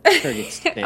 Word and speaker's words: Kyrgyzstan: Kyrgyzstan? Oh, no. Yes. Kyrgyzstan.